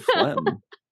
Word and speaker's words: phlegm: What's the etymology of words that have the GM where the G is not pronounced, like diaphragm or phlegm here? – Question.